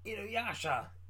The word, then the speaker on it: Inuyasha: Inuyasha! Inuyasha! That's how you say it, right? – Yeah.